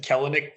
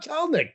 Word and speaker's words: Kelenic: I'd be like, oh, Kelenic, no problem. The E is silent, apparently.